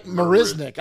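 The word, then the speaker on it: Marisnick: But those two guys, look, Solak, the signing of Mazarek, that they signed, that kind of, huh? How do you say it? – Jack Marisnik. I said Mazarek.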